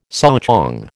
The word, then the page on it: souchong: American English